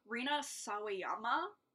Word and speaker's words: Rina Sawayama: Oh, Rina Sawayama? I don't know if I'm saying that right. – That sounds right.